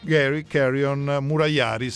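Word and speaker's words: Gary Carrion-Murayari: Da questo punto di vista, a firma di Bonami e del suo co-curatore, che si chiama Gary Carrion Murayari, se la pronuncia è giusta. Francesco, è giusta la pronuncia? – Perfetta, ineccepibile.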